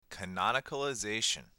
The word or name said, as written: canonicalization